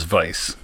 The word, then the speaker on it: Weisz: There's the pronunciation, American, which is Weisz, and then there's the British pronunciation, which is Weisz. – Weisz. So I've always heard it as vice.